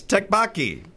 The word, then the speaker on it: tteokbokki: Yes, tteokbokki. Did I say that right? Did I get it? Oh, I did. Wow. – You got it.